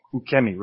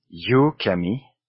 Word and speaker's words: ukemi: One is yukemi, yukemi with a Y at the beginning. So it softens the U going into yukemi, but there is no Y in that sense. The U is a standalone character in Japanese. Ke is a standalone character and mi is a standalone character. So it is ukemi.